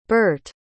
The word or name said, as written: Berthe